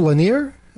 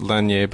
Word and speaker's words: Lanier: We have Jerome Lanier. Am I pronouncing that right? – Yeah, the last part is, I think, French or French-derived, so it's Lanier, but I don't know.